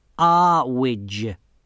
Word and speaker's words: Earwig: Well, I've come to see Anagramma, Mrs. Earwig. It's pronounced Arwidge.